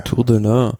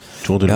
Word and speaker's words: Tour de l'Ain: Tour de l'Ain. Tour de l'Ain.